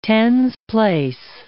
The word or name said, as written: tens place